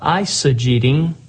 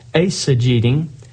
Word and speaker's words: eisegeting: It's easy sometimes to get into the habit of what's called eisegeting, or perhaps pronounced a little bit more correctly, eisegeting.